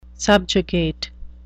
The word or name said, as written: subjugate